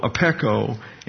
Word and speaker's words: apecho: And the concept here behind this word that we are noting here, it's spelled A-P-E-C-H-O, apeko, and it has to do with the idea of a receipt indicating payment in full.